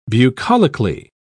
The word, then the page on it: bucolically: British English